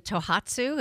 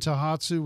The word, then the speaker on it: Tohatsu: How does, I hope I pronounce this correctly, Tohatsu, is that correct? – Mm-hmm.